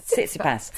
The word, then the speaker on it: Tsitsipas: Somebody told me that you write sits, S-I-T-S, yeah. And then ipas, but you put the T in front, but you just, it's sits, ipas, Tsitsipas.